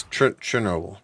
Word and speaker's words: Chernobyl: It's Chernobyl. But you Chernobyl. Say it like with a T, but like put a little H in there.